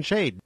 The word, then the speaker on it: Shad: Realmente la palabra hebrea Shad, S-H-A-D, algunos la pronuncian Shade.